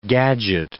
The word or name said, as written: gadget